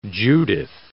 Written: Judith

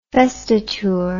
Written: vestiture

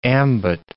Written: ambit

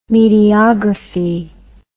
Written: mediography

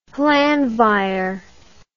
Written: Llanfair

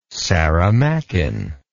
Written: saramaccan

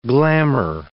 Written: glamor

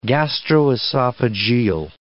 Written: gastroesophageal

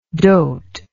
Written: doat

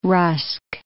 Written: rusk

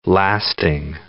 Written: lasting

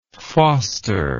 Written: fosteringly

